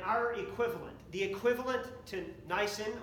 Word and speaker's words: Nisan: month Nisan, not the car, but a different pronunciation slightly.